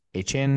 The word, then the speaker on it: Achane: But obviously you have Raheem Mostert, Devon, I guess we were just informed that, I guess it's pronounced Achane now, but I'm still probably going to say Achane.